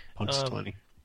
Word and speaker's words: Punxsutawney: Now, the thing with Groundhog Day was he was trapped in this small town, Punxsutawney, Pennsylvania, right? Is that how you say it? – Punxsutawney.